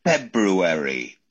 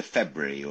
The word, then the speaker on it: February: February. Do you know many people who pronounce it properly like that, as opposed to February or something like that?